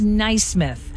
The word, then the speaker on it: Naismith: In 1891, Dr. James Naismith. I think that's how you say his name. N-A-I Naismith.